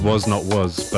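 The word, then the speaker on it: Was (Not Was): I always thought it was called Was Not Was, but I looked it up just to make sure. And they're saying, pronounce it like this. Was, not was.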